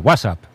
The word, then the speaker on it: WhatsApp: No, peor aún, con acento en la primera, WhatsApp, ¿no? – WhatsApp.